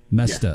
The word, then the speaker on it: Mesta: Mesta.